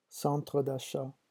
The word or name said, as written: centre d'achat